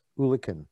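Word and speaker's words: eulachon: So is it pronounced Ulican? – Yes, Ulican.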